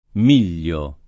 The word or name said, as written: Miglio